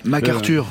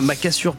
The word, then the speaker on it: MacArthur: MacArthur. – Oui, c'est ça. J'avais pas la prononciation. Je connais MacAssure Park.